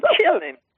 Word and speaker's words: Chillin: C-H-I-L-L-I-N. Chillen.